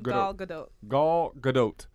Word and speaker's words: Gal Gadot: Gal Gadot. That's right. Afloat. – Gal Gadot.